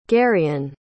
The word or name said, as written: garion